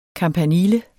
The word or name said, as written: kampanile